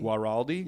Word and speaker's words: Guaraldi: Guaraldi.